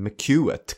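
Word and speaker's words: McCouat: The Life and Death of Mummy Brown från Journal of Art in Society and Philip McCatt. Eller McCuett kanske ska uttalas.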